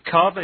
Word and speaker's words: kava: I'm thinking particularly of a meeting you had on the island when this was just starting, when several cups of kava, is that how it's pronounced? – Yes.